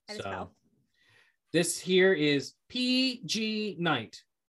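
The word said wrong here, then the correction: Pignite: PG Knight? – Pig Knight. – Pig Knight. You're right. Sorry.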